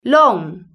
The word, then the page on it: long: British English